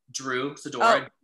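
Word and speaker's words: Druex Sidora: Drew Sidora. – Oh. – D-R-U-E-X Sidora.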